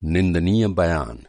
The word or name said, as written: निंदनीय